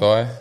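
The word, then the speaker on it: Opetaia: Jai Opatia, is that how you pronounce the last name? – Jai Opatia.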